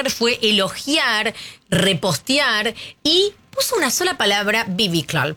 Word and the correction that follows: biblical: Perdón, no se pronuncia así. Biblical, que es bíblico, como algo súper épico.